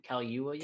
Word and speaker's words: Kaluuya: Also, Daniel, how do you say his name? Kaluuya? – Yeah.